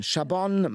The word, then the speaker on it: Chabon: Skurril und schlau spannend von Michael, wie wird er wohl ausgesprochen? Chabon. Chabon, Michael Chabon.